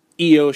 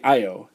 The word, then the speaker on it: Io Shirai: Kairi Hojo, Mayu Iwatani, and Io Shirai. Many times I've heard people say Io. It is Io.